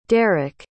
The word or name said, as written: darick